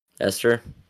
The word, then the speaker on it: Esther: Esther? Is that how you say her name? I haven't seen the movie in so long. – Esther? Yeah, just pretend the H isn't there.